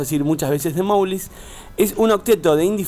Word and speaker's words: Mowglis: Mowglis, ¿cómo se dice? – Mowglis. – Es como Mowgli, el del libro de la selva.